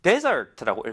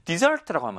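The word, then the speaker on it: desert: 일단 강세가 앞에 있을 때 desert라고 읽으면 사막입니다. 그런데 뒤에 이제 파란색이 E가 뒤에가 있잖아요. dessert라고 합니다. dessert, 그 먹는 디저트는 S가 두 개이고요. 지금 S가 하나잖아요. dessert라고 하면 동사가 되고 버리다 라는 뜻입니다.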